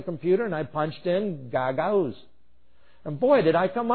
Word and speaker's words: Gagauz: At the time, I did not know how to say it. It was G-A-G-A-U-Z, so Gagauz or something like that was how I was saying it. And I learned later that you do pronounce it Gagauz.